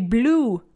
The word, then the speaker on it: blew: They blew the whistle. Blue como el color azul, pero se escribe B-L-E-W. Pero se pronuncia, no es blue, como flew, tampoco es flu y blue.